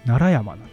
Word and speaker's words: 平城山: で、そのところに、平らな城に山と書いて、平城山って書く場所があるんですけど、これ、平城で奈良って読ませるんですよ。 – 奈良山なんですよ。